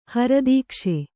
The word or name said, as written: ಹರದೀಕ್ಷೆ